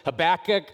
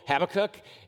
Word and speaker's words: Habakkuk: Some people pronounce it Habakkuk, other people Habakkuk, and either way is acceptable.